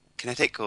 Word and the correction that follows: Kinect: or? – It's actually Kinect.